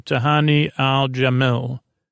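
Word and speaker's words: Al-Jamil: Uh, she also mentioned uh, uh that she's Baz Luhrmann's muse, and this is where she says the pr- proper pronunciation of her last name, Ah Jamil Jamil Ah Jamil. so Tahani Al Jamil.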